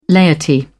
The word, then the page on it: laity: British English